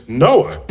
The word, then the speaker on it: NOAA: And but if you pronounce the acronym, it's NOAA.